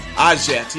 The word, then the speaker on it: Ajit: Ajit, yeah.